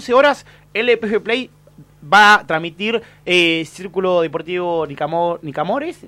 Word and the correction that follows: Nicanor: Nicanor. – Porque a veces lo digo mal.